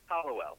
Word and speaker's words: Hallowell: Hallowell.